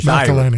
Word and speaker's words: McElhinney: And Ann McElhinney the pronunciation. She's McElhinney. – Irish. Yeah, McElhinney.